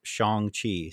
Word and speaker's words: Shang-Chi: saying it's pronounced Shang-Chi, so I did watch Shang-Chi.